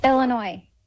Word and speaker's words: Illinois: Illinois.